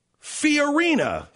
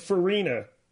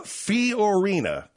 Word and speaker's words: Fiorina: How do you pronounce the name, Rance? Fiorina. That's what I said. – No, you said Farina. – Farina? Fiorina. Carly Fiorina.